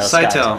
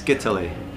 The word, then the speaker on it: Scytale: How do you say Scytale? – Scytale. Scytale works. It's well. Scytale. If you want to use the Greek, which is where the word comes from, it's Scytale, and it's a form of ancient cryptography,